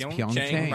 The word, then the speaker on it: Pyeongchang: That's how it's said. – That's Pyeongchang.